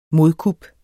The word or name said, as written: modkup